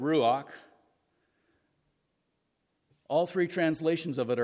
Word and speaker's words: ruach: In Old Testament Hebrew, there is a word, It is pronounced ruach.